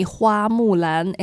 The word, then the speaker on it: Hua Mulan: It's not Mulan. It sounds the same, similar, but it's not the same. To me, Hua Mulan is the name, not Mulan. So hope everybody can pronounce it right.